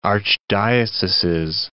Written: archdioceses

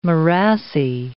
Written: morassy

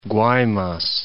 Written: Guaymas

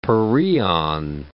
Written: pereon

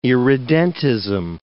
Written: irredentism

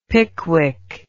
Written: pickwick

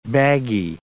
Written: baggy

